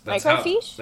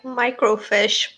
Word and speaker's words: microfiche: That's microfiche? That's how it's pronounced. – Yeah. – It sounds like you're saying it like microfish, but with like a European accent.